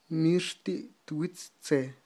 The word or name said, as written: mixti' twitz tze